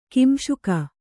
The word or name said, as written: ಕಾಂಸಕ